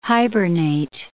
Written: hibernate

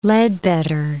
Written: Ledbetter

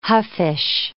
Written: huffish

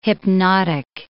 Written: hypnotic

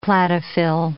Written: cladophyll

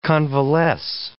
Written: convalesce

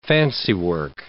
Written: fancywork

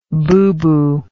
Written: booboo

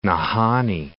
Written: Nahanni National Park

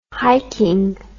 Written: hiking

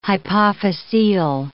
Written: hypophysial

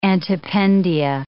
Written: antependia

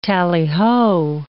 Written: tallyho

